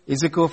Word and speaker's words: Ezekiel: In case you don't know what I pronounce, E-Z-E-K-I-E-L, Ezekiel.